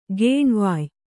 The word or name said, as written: ಗೇಣ್ವಾಯ್